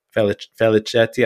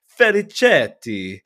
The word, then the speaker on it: Felicetti: Felicetti, I'm assuming it's the like Italian pronunciation. – You really got to pronounce it, yeah. – Felicetti.